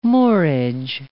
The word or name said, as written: moorage